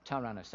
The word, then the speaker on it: Tyrannus: Did I pronounce that right, David? That about right? Tyrannus, thank you.